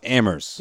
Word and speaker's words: Amherst: We know it's pronounced Amherst. – Yeah. – You don't put the H in there.